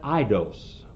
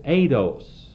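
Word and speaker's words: Eidos (εἶδος): Now German would pronounce that Eidos, but it's not Eidos. There's no ice and ace, as they say. It's Eidos.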